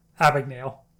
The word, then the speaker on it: Abagnale: That's pretty funny because that's a plot in the movie in the book as well, as no one can pronounce his last name. Abagnale. – There you go.